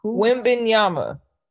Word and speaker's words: Wembanyama: How do you say it? Wanabon? – Wimbenyama. – Wimbenyama.